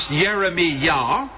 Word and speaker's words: Jeremiah: Additionally, such familiar names as Isaiah, Isheyah, Jeremiah, which in Hebrew is pronounced Yeremiah,